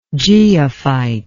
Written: geophytic

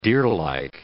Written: deerlike

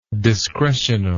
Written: discretional

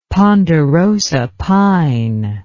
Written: ponderosa pine

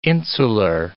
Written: insular

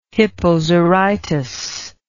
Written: hippozarytus

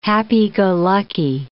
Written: happy-go-lucky

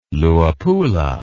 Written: Luapula

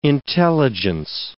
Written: intelligence

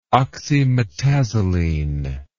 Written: oxymetazoline

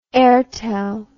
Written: airtel

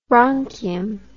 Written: bronchium

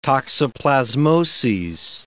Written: toxoplasmoses

